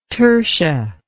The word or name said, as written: tursha